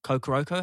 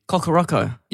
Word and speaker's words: Kokoroko: Or uh, yeah, Kokoroko has been a, a very Kokoroko. – Yeah, yeah. Oh, I've been saying it wrong.